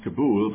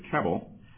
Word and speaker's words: Kabul: There was little dispute that Abdul Haq's killing and the bombings of the Red Cross warehouse compound in Kabul, by the way, it should really be pronounced Kabul, but I think everybody here pronounced it Kabul, the latest in the string of errant airstrikes that have cost the campaign support in the Muslim world, were setbacks.